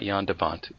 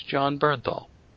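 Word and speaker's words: Jon Bernthal: It's not Jan DeVant. It's John Bernthal.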